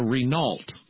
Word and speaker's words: Renault: He called it a Renault. That's how we pronounce it.